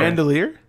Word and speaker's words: bandolier: She has like a big like mandoiler and it's like blowing in the wind. – It's dope. – Bandolier? – Bandolier, thank you.